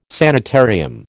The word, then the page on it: sanitarium: American English